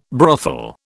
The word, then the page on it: brothel: British English